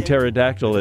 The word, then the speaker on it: pterodactyl: No, Dave, because the P in pterodactyl is silent.